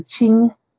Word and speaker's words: Qing: And Qing is also spelled with a Q, so it's Q-I-N-G. So just like the Qin we had earlier, they're a slightly different tone. You don't really have to say the tone in English, but it's the Qin and the Qing. The Qing dynasty, you can think of Qing rhymes with Ming, but we say it's smiling again.